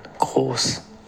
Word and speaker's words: Gross: Yeah, so that would be pronounced Pascal Gross in German. – Gross.